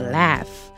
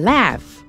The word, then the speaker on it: laugh: 然后 laugh, L-A-U-G-H, 就是哈哈哈哈，大笑，笑得很开心，叫做 laugh。那这个小朋友请注意， G-H 是念成 f 的音哦，所以我们是念成 laugh, laugh。好，那下面他说 We make a lot of noise, make noise。 Make, M-A-K-E, 是制造的意思。